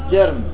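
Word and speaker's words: جِرْم: لاحظوا يقول وترك استقبال جرم النيرين، طبعا من الأخطاء الشائعة بين الناس أن تلفظ جرم بمعنى جسم، بينما جرم بالضم بمعنى الجريمة والذنب. أما بمعنى الجسم أو الجسد فهو بالكسر جرم، وهذا من الأخطاء الشائعة.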